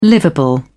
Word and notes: livable: British English

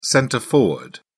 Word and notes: centre forward: British English

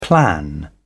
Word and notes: plan: British English

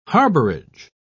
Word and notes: harborage: British English

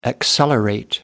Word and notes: accelerate: American English

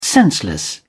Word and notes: senseless: British English

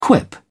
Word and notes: quip: British English

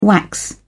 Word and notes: wax: British English

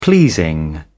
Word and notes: pleasing: British English

